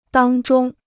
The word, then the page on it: 当中: dāng zhōng